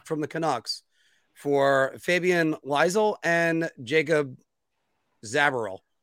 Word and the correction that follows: Zaboral: Zaboral. – Thank you. Damn it. Anyway, I put the emphasis on the wrong syllable.